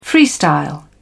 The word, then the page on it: freestyle: British English